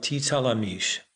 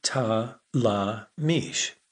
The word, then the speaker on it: Titalamish: The story was called The Princess of Titalamish. Theo insisted that Lamisha learn to pronounce the name correctly, like this, one syllable at a time, Titalamish.